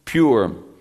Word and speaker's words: Pur: It's pronounced Pur, P-U-R.